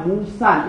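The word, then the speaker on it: موسیً: الف رو که بیاندازم یه نون را باید بگم موسن. این الف رو که تلفظش نمیکنم، وقت در موسا اعراب میشه.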